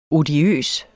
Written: odiøs

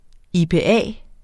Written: IPA

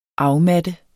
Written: afmatte

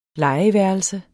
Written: legeværelse

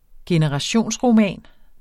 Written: generationsroman

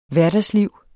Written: hverdagslivet

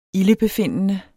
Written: ildebefindende

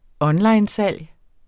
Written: ONLINESALG

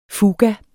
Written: fuga

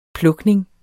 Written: plukning

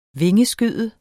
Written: vingeskyde

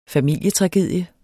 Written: familietragedie

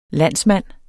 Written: landsmand

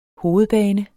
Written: HOVEDBANE

